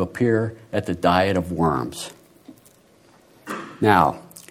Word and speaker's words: Worms: The Diet of 1521 met in the German city of Worms, correctly pronounced Worms.